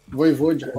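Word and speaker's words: Vojvoda: como é que fala, né? Vovoja. Vovoja Voivode. Voivode, é.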